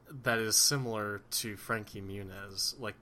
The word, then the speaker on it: Muniz: Is that how you pronounce his name? – Yeah. Frankie Muniz? Yeah.